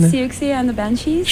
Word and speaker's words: Siouxsie and the Banshees: That was Dear Prudence by, how do you pronounce that band? – Siouxsie and the Banshees.